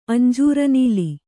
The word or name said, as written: ಅಂಜೂರನೀಲಿ